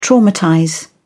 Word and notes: traumatize: British English

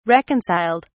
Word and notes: reconciled: British English

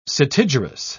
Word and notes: setigerous: British English

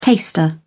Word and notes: taster: British English

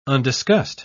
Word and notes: undiscussed: British English

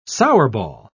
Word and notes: sourball: British English

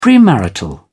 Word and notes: premarital: British English

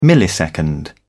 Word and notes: millisecond: British English

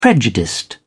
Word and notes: prejudiced: British English

